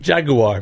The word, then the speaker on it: Jaguar: Jaguar. Make it three syllables.